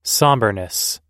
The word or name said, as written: somberness